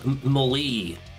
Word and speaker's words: melee: It's called melee. It's pronounced differently in different languages. Yes, melee.